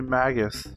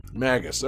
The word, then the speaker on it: Magus: How do you pronounce the name of the guy from Chrono Trigger? – I always say Magus. – Magus. Okay, I'm going with that too.